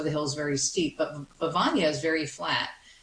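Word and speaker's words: Bevagna: I want to know about the birds of Bivagna. Did I say this correctly? – Oh, yes, you did. Well pronounced.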